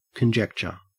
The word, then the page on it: conjecture: Australian English